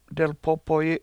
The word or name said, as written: delpOpoyi7